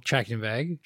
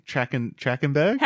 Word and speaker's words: Trachtenberg: Michelle Trachtenberg. – Trachtenberg.